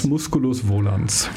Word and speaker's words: Musculus volans: Eine Maus, die ins Weltraum, in den Weltraum eintritt oder so, wenn ich das richtig verstanden habe, Musculus volans? Nee, wie spricht man es richtig aus? – Musculus volans.